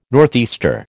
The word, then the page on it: northeaster: American English